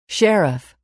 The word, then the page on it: sheriff: [ʃérif]